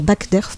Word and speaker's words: Backderf: Euh, ce vendredi 14 octobre, de 14h à 19h, il y a euh, Derf MacDerf, non, Backderf, pardon, j'ai mal prononcé son nom, euh, qui sera en dédicace au Bidibule.